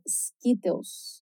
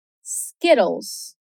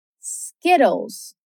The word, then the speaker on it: Skittles: Bom, aqui a gente tem três dicas para prestar atenção. A primeira é o som da vogal I. – Quando o I aparece antes de duas consoantes repetidas, ele vai ter um som curto. Então vai soar um pouquinho como o nosso I do português. Por exemplo, different se escreve com dois Fs. Para que o I tenha esse som curtinho e não de I, como a gente geralmente escuta. Então, de novo, skit, Skittles.